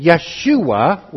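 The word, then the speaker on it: Yeshua (ישוע): Yeshua would be the way that you would pronounce it in Hebrew.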